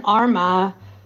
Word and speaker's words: Armagh: I need to correct you on your pronunciations. It's Sligo, Mayo, and Galway. Then Armagh, Tyrone, and Offaly. And then Leitrim, Donegal, and Laois.